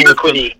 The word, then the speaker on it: Urquidy: I mean, Houston all around has been fantastic this year, and uh, with, you know with Justin Verlander and Jose Urquide, or uh, Urquidy, I believe I, I, said it correctly. I'm terrible. – Urquidy, yeah.